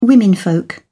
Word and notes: womenfolk: British English

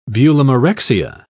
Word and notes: bulimarexia: British English

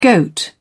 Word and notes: goat: British English

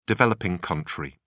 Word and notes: developing country: British English

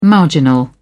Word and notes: marginal: British English

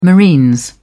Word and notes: marines: British English

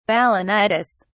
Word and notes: balanitis: British English

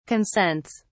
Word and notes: consents: British English

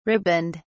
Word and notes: riband: British English

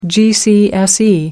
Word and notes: gcse: American English

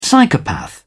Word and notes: psychopath: British English

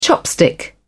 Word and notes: chopstick: British English

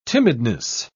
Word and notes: timidness: British English

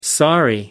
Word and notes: sorry: American English

